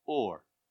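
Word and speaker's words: Ur (אור): and you pronounce that Ur.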